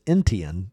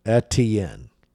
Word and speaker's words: Etienne: And he says, I've noticed that some of the people who cover Atlanta United have been pronouncing Etienne's name as if it is Entian with an extra sound in the first syllable. Is there a reason for that? I have no idea. I was just looking up the pronunciation guide, and it is Etienne.